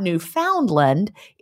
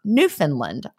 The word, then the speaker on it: Newfoundland: Just to help you start, it's not Newfoundland. It's pronounced Newfoundland. I almost did it, right? I was saying Newfoundland. I was putting the D in there, though.